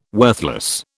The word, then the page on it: worthless: British English